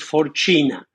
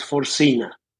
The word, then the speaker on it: Forcina: Well, the name in Italian, I'm Italian. The name, okay. Well, I'm American now, but I was born in Italy and the name is Forcina. But because I lived 20 years in Argentina and the Spanish pronunciation is Forcina.